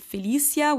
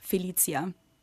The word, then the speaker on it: Felicia: Yeah, we would say Felicia in German. Some mm-hmm. people might pronounce it Felicia, which more like an S sound. Yeah. But my parents decided that my name is pronounced Felicia.